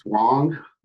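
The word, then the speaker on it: Huang: It's actually really good, getting into the details of the Walter Hang crash. Is that how I pronounce his name correctly? – It's Wong.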